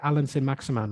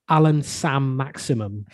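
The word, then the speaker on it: Allan Saint-Maximin: No one can say Alan San Maximan right. It's always like Alan Sam Maximum.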